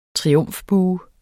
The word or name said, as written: triumfbue